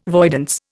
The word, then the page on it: voidance: Indian